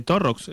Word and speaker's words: Torrox: En referencia a la noticia, si te parece, vamos a escuchar una entrevista del alcalde de Torrox.